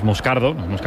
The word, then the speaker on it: Moscardo: Como bien ha pronunciado Unai, es Moscardo, es brasileño, sí.